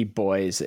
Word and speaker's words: buoy: B-U-O-Y, boy. Because we say, we pronounce that boy.